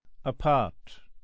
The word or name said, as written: apart